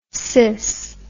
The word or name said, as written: sys